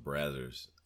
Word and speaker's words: Brazzers: Said Brazers. – I think it's pronounced Brazzers.